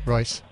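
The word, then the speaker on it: Ruess: Nate Ruse, the former lead singer of Fun, turns 42 today. – Royce. – Nate, really? Royce? – Royce. – Oh, wow. – Royce. – That's not spelled like that. – No, I know. It's a weird spelling. I believe it's Nate Royce. Royce.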